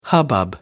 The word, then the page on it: hubbub: American English